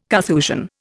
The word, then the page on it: Carthusian: Indian